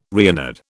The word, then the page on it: Reynard: British English